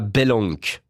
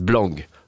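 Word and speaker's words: Blank: Il y a encore d'autres, j'ai mal prononcé. Boris Blanc, voilà, Yellow, pas Belenk, j'ai dit Belenk, Boris Blanc.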